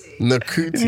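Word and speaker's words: Ncuti: It's Nikita Gatwa from, I think that pronunciation's right. – Is it Nakuti? Nakuti.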